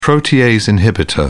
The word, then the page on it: protease inhibitor: British English